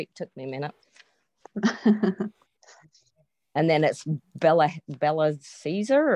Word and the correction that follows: Belshazzar: or whoever that is. – Belshazzar, comes, yeah. – Yeah, comes in. – There you go.